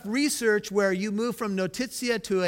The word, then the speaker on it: notitia: notitia. I'll spell it for the note takers. N-O-T-I-T-I-A.